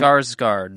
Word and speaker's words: Skarsgård: Skarsgård.